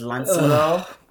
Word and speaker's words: Lancelot: The other thing is that they all pronounce the names the French way. So Lancelot is Lancelot.